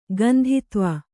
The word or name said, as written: ಕಾಂತತ್ವ